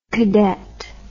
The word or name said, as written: kadett